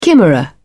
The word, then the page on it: chimera: British English